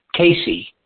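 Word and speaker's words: Caisse: her last name is spelled C-A-I-C-A-I-S-S-E, Casey. Now, if you spell that backwards, you end up with S-E-A-C T, so I just was suggesting that's an easy way to remember it.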